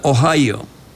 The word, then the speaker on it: Ohio: Ciudad Columbus, en el estado de Ohio, se pronuncia Ohio.